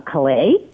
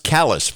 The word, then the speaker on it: Calais: starting with those not in compliance are Calais. – That's why we're going to jump in, because you may not know. It's pronounced Calais for some reason here in our state.